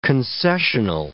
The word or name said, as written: concessional